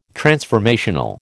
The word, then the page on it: transformational: American English